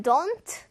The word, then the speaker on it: don't: 자, 원어민들은 don't, t, t 이거를 그냥 묵음으로 발음하는 경우도 있습니다.